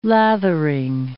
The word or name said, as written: lathering